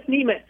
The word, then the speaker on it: Nemetz: We go with Nemetz.